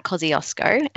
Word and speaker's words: Kosciuszko: Our tallest mountain is Mount Kosciuszko, and that's a very Australian pronunciation of a Polish name.